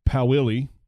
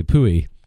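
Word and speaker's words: Pouille: For everybody in America, it's Pauilly. but if you've ever taken French, it's Pouilly. – Oh, that's the one name I want to pronounce correctly. Pouilly.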